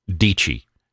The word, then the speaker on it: Deechee: Deechee. It's like peachy with a D. Anyway, it rhymes with peachy.